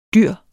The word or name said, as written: dyr